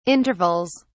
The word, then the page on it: intervals: British English